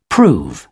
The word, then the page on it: prove: British English